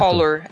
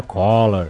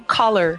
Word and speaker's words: color: Mas na verdade, verdade, ele é mais aberto, seria um collar. – E aí você ainda, ainda percebi na sua pronúncia, a segunda pegadinha, que não seria color e seria sim color. Existe um, um, um sopro entre ah, o nossa, C e o O.